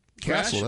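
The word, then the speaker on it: Cassill: Landon, are you sure it's Castle? – Castle, that's Crash? – The only thing I've ever heard is Landon Castle. – That's all I've heard, yeah. – Right? Because it's spelled like it's Cassell.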